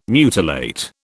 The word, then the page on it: mutilate: British English